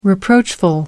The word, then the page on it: reproachful: American English